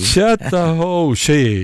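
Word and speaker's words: Chattahoochee: Alan Jackson med Kjell, hur uttalar man chatta ho hej? – Chatta ho she.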